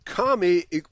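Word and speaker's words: cami: So the Turkish word kami, which equals mosque, is pronounced similar to the English word jammy.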